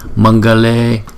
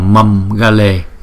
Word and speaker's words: Mangale: Mangale, chứ chúng ta không đọc là mâm gale ha. Bởi vì mâm là chữ M là đọc bằng môi rồi, mà chữ G là đọc bằng cuốn họng thì nó đâu có hợp được, thì chúng ta phải đọc là mangale.